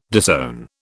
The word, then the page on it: disown: British English